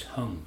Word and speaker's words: hang: And it's also called hung, but it's spelled H-A-N-G, pronounced hung for some reason that I don't fully understand, but whatever.